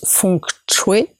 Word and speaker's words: feng shui: Aujourd'hui, en Asie, le feng shui, donc qu'on prononce feng shui, est entré dans le quotidien d'un grand nombre de personnes.